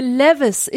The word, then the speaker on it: Levi's: Meine ganze Jugend über, Alter, elf Jahre bis 19 haben alle gesagt Levis 501. Levi's. Das ist für mich eine Levis.